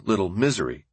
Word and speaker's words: Little Missouri: Peering out of the dining room window into the brilliant prairie light, Roosevelt could take stock of Little Missouri, or Little Misery, as residents pronounced it.